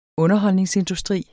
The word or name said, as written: underholdningsindustri